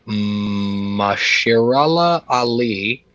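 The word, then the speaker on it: Mahershala Ali: It's I'm going to butcher this. Mashirala Ali. – Oh, that's right.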